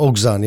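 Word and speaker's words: Oguzhan: Hoe spreek je het uit? Oguzan? – Uh, eh, Oguzan, ja. Oguzan, ja.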